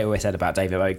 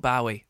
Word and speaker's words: Bowie: It's one of the things, like, it's Bowie, not Bowie, and it just gets to me. It just absolutely gets to me. It's Bowie. He pronounced it Bowie. His son, Zoe Bowie, pronounces it Bowie.